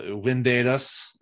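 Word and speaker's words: Vindedas: There's a Windedas. I keep wanting to say that V, but because it's spelled with a V, but it's pronounced with a W.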